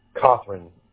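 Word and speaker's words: Cothren: O, T as in Tom, H, R-E-N. – Pronounce it for me. How do you pronounce it? – Catherine.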